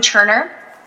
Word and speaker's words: Turner: My name is Robin Turner.